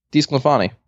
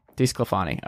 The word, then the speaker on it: DeSclafani: Like, how do you say his last name? – DeSclafani. – DeSclafani, okay.